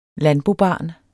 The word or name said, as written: landbobarn